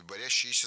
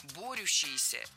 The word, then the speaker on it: борющиеся: Борящиеся – неверно, причем в этом слове сразу две ошибки. Во-первых, ударение должно падать на первый слог. Во-вторых, в этом слове суффикс «ющ», а не «ящ». Борющиеся – это правильный вариант.